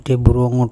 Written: Theburongo

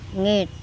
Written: ngeT

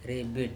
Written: rEbiT

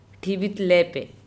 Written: ThibitlEpe

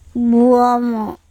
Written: buOmo

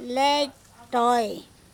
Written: lec Toe

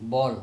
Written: bol

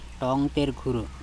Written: TongtErkHuro

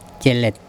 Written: celet

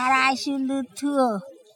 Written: araSulutHuo

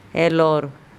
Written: elOr

